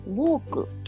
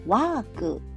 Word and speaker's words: walk: ウォークというふうに日本語ではカタカナ英語で発音しますがワークの方がニュアンスとしては近いかなと思いますでワークワ w ーク。